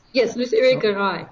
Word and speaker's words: Luce Irigaray: Is it Luce Irigaray? Is that right? – Yes, Luce Irigaray.